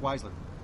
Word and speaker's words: Weisler: Just Kirk Weisler. Yeah, just at Kirk Weisler. Yeah. Kirk Weisler.